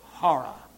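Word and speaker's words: hora (ὥρα): The Greek word hora, that's omega, the long O in the Greek. And whenever you have the rough breathing, it always carries the H sound. So hora, hora.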